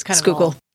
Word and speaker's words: Schuylkill: He recommended a descent rate and advised me that I should break out of the clouds at 900 feet over the Schuylkill River. – That's fine. If you say Schuylkill a little bit, that's fine. – Schuylkill River. – Not quite right. – Schuylkill River. – Schuylkill. Mushed together.